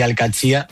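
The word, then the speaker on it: Al-Qadsiah: Bueno, se suele decir Al-Qadsiya.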